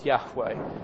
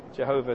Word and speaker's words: Yahweh: The correct way of saying it is Yahweh. Jehovah is a kind of a hybrid word. I won't go into the details, but it's not quite the right pronunciation.